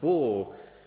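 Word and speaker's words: bore (בור): Now the word here for pit, according to Strong's, is from the word, and I'll spell it, B-O-W-R, which Strong's tells me is pronounced bore, which is translated as pit, well, or cistern.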